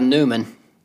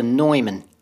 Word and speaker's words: Neumann: It's not a Neumann. It's a Neumann. – No, it's a Neumann to me. – Yeah, they were saying, it took me forever to figure out what they were saying. But they were saying Neumann. It's N-E-U-M-A-N-N, but apparently it's pronounced Neumann.